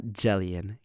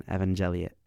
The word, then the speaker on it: Evangelion: First of all, correction on my pronunciation. Neon Genesis Evangelion. Not Jellion, Gellion, Evangelion.